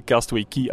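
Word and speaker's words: Castaway Cay: Nu, Castaway Key, hè, geschreven Castaway Key, C-A-Y, eh, zo raar als je het kan, maar het is wel tegen Castaway Key uitgesproken, is, is ja, dat is dat is gewoon zot, hè?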